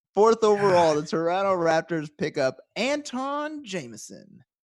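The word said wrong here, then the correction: Antawn Jamison: Gets picked up, it's spelled Anton, but it's Antoine. – Yeah, I'm sorry. Antoine Jameson.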